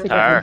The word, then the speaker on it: tower: Tower. – No. Wow. You can't tower. Use the tower. W right in the middle there.